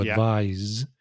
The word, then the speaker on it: advise: So when it's a verb, the S is pronounced like a Z sound. So to advise.